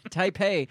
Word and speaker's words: Taipei: You put the emphasis on the wrong syllable. – I'm sorry. Taipei says it will respond to any incursion.